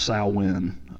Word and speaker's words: Samhain: It is written as Samhain, but it's actually pronounced Samhain.